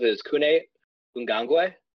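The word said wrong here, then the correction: Kene Nwangwu: Kene Wangu. – Kene Wangu. For some reason, the thing that I read said that the first E in his first name was an U, so they didn't know either.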